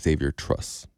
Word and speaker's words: Xavier Truss: There's only one player that was ranked outside of the top 100 starting on their line, and it's Xavier Truss. Am I saying that correctly? – Yes, that is correct.